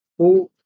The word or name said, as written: u